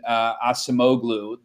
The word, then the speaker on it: Acemoglu: When I was in China, for instance, I remember reading a book by, I may get his name wrong, Damon Acemoglu, an economist. Yeah. Sure, I'm doing terrible damage. – The MIT, he did a great job pronouncing him, better than me, the MIT economist.